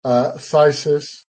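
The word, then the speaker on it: phthisis: Phlegm, thysis, the PH there is actually silent, even though it's written.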